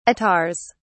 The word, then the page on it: attars: British English